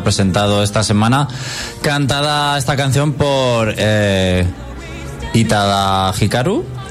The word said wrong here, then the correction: Utada Hikaru: ¿Lo he dicho bien? Utada Hikaru Utada, Hikaru.